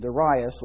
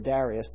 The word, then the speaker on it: Darius: And Darius, or Darius, depending on what syllable you put the accent, the Median, took the kingdom, being about 62 years old.